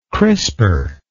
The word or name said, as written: crisper